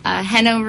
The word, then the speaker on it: Geno: And I know because when I went to write out my comments, I was pronouncing your name Geno because it's G-E-N-O. – Yeah, G-E-N-O. And then the last name would be R-A-M-I-R-E-Z. You should probably find me under Hano Ramirez.